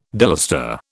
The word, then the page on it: delustre: British English